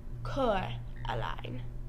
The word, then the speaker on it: Coraline: Coraline. Coraline, as she says in the movie. Because everybody mispronounces her name.